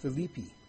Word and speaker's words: Philippi: I've recently, through some lectures I've heard from those who are instructors at the Foundation Institute, have learned that this is properly pronounced Philippi.